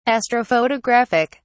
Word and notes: astrophotographic: British English

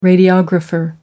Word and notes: radiographer: American English

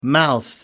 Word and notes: mouth: American English

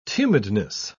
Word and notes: tumidness: British English

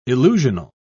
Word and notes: illusional: British English

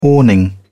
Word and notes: awning: British English

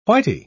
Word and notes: whity: British English

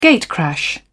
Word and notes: gatecrash: British English

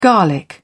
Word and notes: garlic: British English

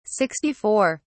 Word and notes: 64: British English

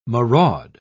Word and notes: maraud: British English

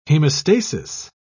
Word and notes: hemostasis: British English

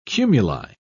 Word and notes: cumuli: British English

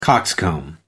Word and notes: cockscomb: American English

